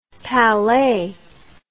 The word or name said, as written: palais